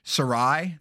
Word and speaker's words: Serai: I don't know how to pronounce her name. But it's S-E-R-A-I. How did you pronounce it? – S-E-R-A-I. Sorry. Yeah. – Sorry. So I said Sarai. That's how I pronounced it.